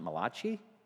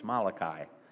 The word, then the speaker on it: Malachi: And we're in Malachi, where some people pronounce it Malachi. No, it's Malachi. Malachi.